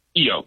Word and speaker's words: Keyo: Keyo, K is like a key with an O on it.